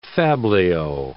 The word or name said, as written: fabliau